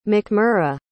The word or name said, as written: MacMurra